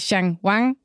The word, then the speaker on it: Shang Wang: Og et sted, I hvert fald har købt øh, op på sådan distributionssiden, det er i Kina, hvor I har købt, ja, nu ved jeg ikke, om jeg udtaler det rigtigt, Shang Wang. Det er helt rigtigt.